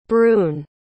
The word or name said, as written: brune